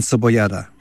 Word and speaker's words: cebollada: Carne frita, and let me see if I can pronounce this correctly, and cebollada.